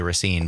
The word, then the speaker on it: Racine: Also had the smartness and the quickness to call out Racine, Wisconsin, and knows how native Raciners pronounce it even. – Even other people around the state often say Racine, but Racine, if you live there, is how you say it.